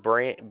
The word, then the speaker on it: Brant: The last name is Brant, Brant.